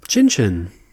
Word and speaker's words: Cin Cyn: Cin Cin. – So it's spelled C-I-N-C-Y-N. So it's a play on the Italian cheers, Cin Cin.